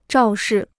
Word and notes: 肇事: zhào shì

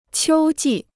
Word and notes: 秋季: qiū jì

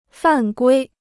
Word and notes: 犯规: fàn guī

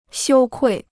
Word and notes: 羞愧: xiū kuì

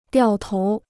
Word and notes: 掉头: diào tóu

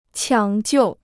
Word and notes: 抢救: qiǎng jiù

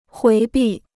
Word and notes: 回避: huí bì

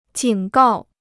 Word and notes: 警告: jǐng gào

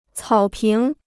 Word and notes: 草坪: cǎo píng

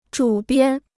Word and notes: 主编: zhǔ biān

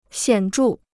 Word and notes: 显著: xiǎn zhù

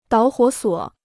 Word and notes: 导火索: dǎo huǒ suǒ

